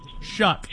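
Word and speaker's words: Shough: I think Tyler Shuff, whatever his name is, Shaw, however you pronounce Shuck. – It. – Shuck, like Shuck. Shucking oysters. Shuck.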